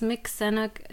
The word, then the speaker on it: Mixinook: Is Mixinook, is that correct? – Yeah, I mean, it's more like Sinok, like a long E.